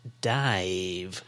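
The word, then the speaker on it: DIIV: So the band Dive. So that's not spelt how you'd think. It's capital D, capital I, capital I, capital V. D-I-I-V, but pronounced Dive.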